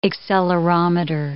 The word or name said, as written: accelerometer